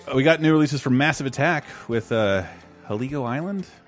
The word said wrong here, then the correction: Heligoland: Heligoland. – Heligoland. Is that how it's said? Yeah. Heligoland. That makes more sense if you know Legoland.